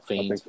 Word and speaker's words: Fiennes: Yeah, so, Fiennes.